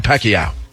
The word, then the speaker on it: Pacquiao: Manny Pacquiao. How are you supposed to say his name? – Pacquiao.